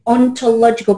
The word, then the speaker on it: ontological: What is an ontological coach? And did I say it right? – You said it absolutely correctly.